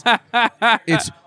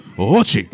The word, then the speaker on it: Ouchi: O-U-C-H-I? It's Ouchie. – Oh, that's terrible. I'm okay. sorry. – Because the O-U is long O. Ouchie.